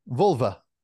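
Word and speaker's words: Völva: And Viking healers, I believe they were called, and I want to say it properly because it's V-O-L, Volva. Is that the right way to say it? Say it? – Yeah, Volva. – Volva.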